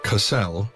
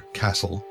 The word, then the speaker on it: Cassel: Cassel, never Castle, a common mispronunciation that also irritates the natives, is the resort world of the Gauss system, the ultimate destination for hundreds of millions of human tourists every year.